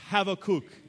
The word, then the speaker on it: Habakkuk: It's actually it's a Semitic term, and it's like my family have a kook in it. Okay, it's a good way for you to remember. Uh, but it's Habakkuk is how you say it.